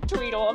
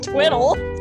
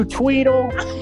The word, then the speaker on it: Tweddle: It's spelled T W E D D L E. – Oh my god. – Not Twiddle. – No, Twitter, you Tweedle.